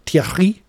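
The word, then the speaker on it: Thiaré: So you're going to hear in this podcast from Brad Guzan, from Caleb Wiley, from Atlanta United's new signing, Jamal Thierry, or Thierry, as I was told by Sarah to pronounce it.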